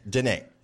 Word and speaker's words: Diné: Diné.